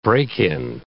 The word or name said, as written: break-in